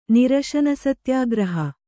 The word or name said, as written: ನಿರಶನಸತ್ಯಾಗ್ರಹ